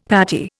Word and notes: patty: Indian

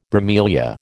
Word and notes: bromelia: American English

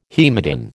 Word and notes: haematin: American English

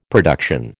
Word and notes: production: American English

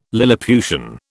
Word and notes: lilliputian: British English

